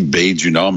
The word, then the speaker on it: Bay du Nord: Il y a, ça, se dit drôlement, ça se dit Bay du Nord, mais ça s'écrit B-A-Y parce que c'est à Terre-Neuve-Labrador.